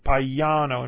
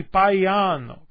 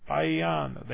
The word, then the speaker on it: Paiano: And in Italian, they are actually pronounced. So people will pronounce my name Paiano. In fact, I usually will do that. So my name is Frank Paiano because that's easier. But in Italian, it's actually Paiano. Paiano. They hit every vowel.